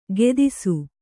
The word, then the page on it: ಖೇದಿಸು: khēdisu